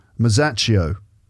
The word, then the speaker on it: Masaccio: Masaccio.